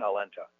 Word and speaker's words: Aulenta: My name is Aaron Alenta. It's spelled A-A-R-O-N. Last name is A-U-L-E-N-T-A.